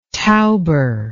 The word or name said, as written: tauber